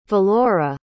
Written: valora

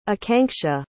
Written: akanksha

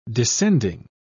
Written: descending

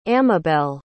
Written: amabel

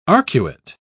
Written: arcuate

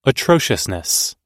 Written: atrociousness

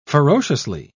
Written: ferociously

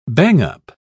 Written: bang-up

